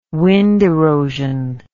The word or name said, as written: wind erosion